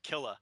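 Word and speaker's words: Ghostface Killah: Yeah, there's the Ghostface Killa. – Yeah. – You see how I pronounced that? I got with the AH at the end. Killa. There's you meant the RZA.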